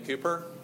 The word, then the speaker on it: Cowper: He did a talk a couple years back on William Cooper. It looks like Cowper, but it's pronounced Cooper.